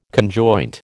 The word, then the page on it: conjoint: American English